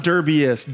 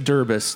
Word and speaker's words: derbist: Not derbiest, derbist. – Oh, thank you for the proper pronunciation.